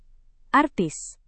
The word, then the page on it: artis: indonesian